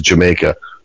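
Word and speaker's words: D'yer Mak'er: I know they spelled it weird, but people don't realize that's actually how you say it. It's supposed to say is Jamaica.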